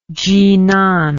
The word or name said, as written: Jinan